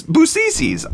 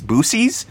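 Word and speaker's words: Buc-ee's: And then Vela saying, Busisi's? It's Busisi's. I know. I know how you're supposed to pronounce it. But I have many friends in Texas, and I know that Texans take it way too seriously. So I intentionally just absolutely destroy it. I know like the joke pronunciation is Boosies,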